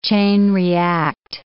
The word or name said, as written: chain-react